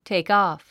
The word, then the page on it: takeoff: téikɔ̀(ː)f; テイクオフ